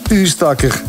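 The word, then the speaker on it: Oostakker: Maar niet voor Lieve de Koning uit Ustakker. Dag Lieve, goedemorgen. Zeg ik dat goed? – Goedemorgen Peter. – Zeg ik dat goed, Ustakker? – Absoluut.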